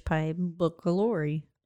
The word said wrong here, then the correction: pylori: You added a syllable. – What is it? – H. pylori.